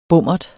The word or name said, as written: bommert